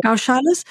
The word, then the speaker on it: goshalas: But you've got mini dairies happening in the middle of cities where there's massive amounts of noise and and also how do you say goshalas is that how you say it goshalas yeah goshalas yeah anyway like just the,